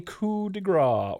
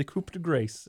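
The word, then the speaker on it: coup de grâce: Finn got the pin and the win after hitting the coup de grace, or the coup de grace, if I was to read it phonetically.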